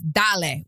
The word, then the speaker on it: dale: And if you guys know, Pitbull always says dale, which means like, all right, like, let's go. – It's dale, dale, dale, dale, dale, dale. So the event was called Dale on Deck and it's spelled D-A-L-E.